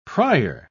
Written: pryer

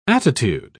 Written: attitude

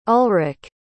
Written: ulrike

